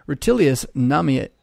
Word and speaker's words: Rutilius Namatianus: Rutilius Namiat, I almost had it. Rutilius Namatianus.